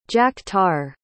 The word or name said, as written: jack-tar